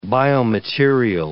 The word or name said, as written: biomaterial